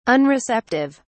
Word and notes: unreceptive: British English

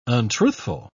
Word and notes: untruthful: British English

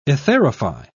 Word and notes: etherify: British English

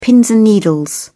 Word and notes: pins and needles: British English

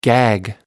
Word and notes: gag: American English

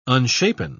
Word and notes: unshapen: British English

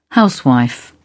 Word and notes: housewife: British English

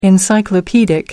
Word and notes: encyclopedic: American English

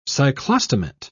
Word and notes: cyclostomate: British English

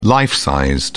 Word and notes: life size: British English

